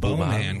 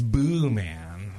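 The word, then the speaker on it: Boeman: M-A-N. She was like, no. It's Boo Man. Like, Boo.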